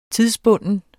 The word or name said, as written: tidsbunden